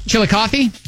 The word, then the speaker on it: Chillicothe: Chillicothe. Is that how you say it? – Chillicothe? – Yeah. – Yep. – Did I say that right? – Yep, you did.